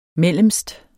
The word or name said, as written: mellemst